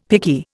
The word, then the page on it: picky: Indian